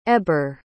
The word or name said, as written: eber